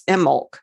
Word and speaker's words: Imbolc: That's all I've got on witch bells, but I would like to mention Imbolc, which actually in Gaelic, it's the B is not pronounced. It's Imbolc in Gaelic.